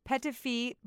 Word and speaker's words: Petitfee: It's spelled PETITFEE, Petafee, Black Pearl and Gold Hydrogel Eye Patch.